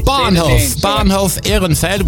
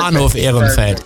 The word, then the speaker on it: Bahnhof Ehrenfeld: Bahnhof Ehrenfeld, which is the station Ehrenfeld. Bahnhof is the train station, so that's the name of it. – Perfect. – Bahnhof Ehrenfeld.